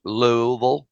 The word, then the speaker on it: Louisville: By the way, that's how you say it, folks. Louisville.